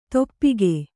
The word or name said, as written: ತುಪಾಕಿ